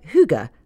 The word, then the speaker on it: hygge: How to Find Your Special Cozy Place suggests that the crucial word be pronounced hygge.